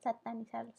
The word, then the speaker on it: satanizarlo: O sea, es una, es una estupidez que quieran recriminarlo y que quieran satinizarlo. ah, ¿Cómo se dice? Satanizarlo. Satanizarlo.